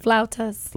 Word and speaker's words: flautas: And flauta, how do you say that? – Flautas.